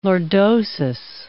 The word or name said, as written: lordosis